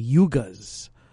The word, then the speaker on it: yugas: We're going to get into a concept called medical astrology and the yugas. That's spelled Y-U-G-A-S, yugas.